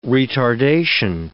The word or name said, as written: retardation